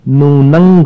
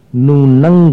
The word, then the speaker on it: नूनं: मोहक्षयाद अनुभवन्नपि नाथ मर्त्यु नूनंग गुणान, अनुस्वार के उच्चारण पर ध्यान देना, नूनंग गुणान गुण, नूनंग गुणान गणयितुन्न तवक्षमेत। अगले अक्षर पर उच्चारण जाकर के समा जाना चाहिए, माने उच्चारण का मेल अगले अक्षर में हो जाना चाहिए।